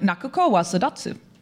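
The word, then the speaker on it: Nakuko wa sadatsu (泣く子は育つ): Nakuko wa sadatsu. – Can I have a look? I studied Japanese. – So did you think you were going to catch her out over it? Yeah. – But I'm probably not going to do much better. Where is it? Oh, yeah, no, you said it right.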